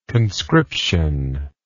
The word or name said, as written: conscriptional